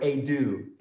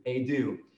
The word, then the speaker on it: idou: That word wow is the Greek word idou, idou. And if you try to spell it in the English, it would be I D O U, the transliteration.